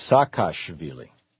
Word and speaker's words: Saakashvili: It's Saakashvili. – Okay. You're better at pronunciation. – Mikhail Saakashvili.